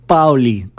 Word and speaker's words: Paoli: en la versión de su compositor Gino Paoli, con acento en la A, el gran seductor al que vamos a dedicar como homenaje esta edición de Crónicas Apasionadas.